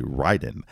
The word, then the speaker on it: Raiden: And just going off of that commercial, if you're ever wondering the proper way to say it, is it Raiden or Raiden? The commercial does say Raiden.